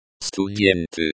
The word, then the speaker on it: Студенты: Студенты. Stress on the first syllable. Сту rhymes with to, then rhymes with then, and tie rhymes with T.